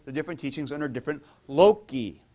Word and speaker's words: loci: And so obviously the plural of locus is loci, or unless you're a church Latin soft C guy, loci. But I would go for loci because I was the hard Latin, okay, classical Latin. So loci. And this is also why, what is Melanchthon's great theological textbook called?